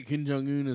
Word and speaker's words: Kim Jong-un: Is it Kim Jong-un? Is yes. – That how I'm saying his name correctly? – It's correct enough.